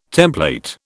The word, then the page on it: template: British English